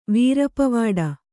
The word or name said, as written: ವೀರಪವಾಡ